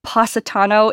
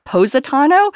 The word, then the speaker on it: Positano: I hadn't realized I was pronouncing Positano incorrectly until the director corrected me that it was Positano and not Positano.